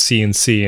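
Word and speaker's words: Sienci: Until I realized it's actually supposed to be pronounced CNC, and it's...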